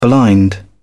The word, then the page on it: blind: British English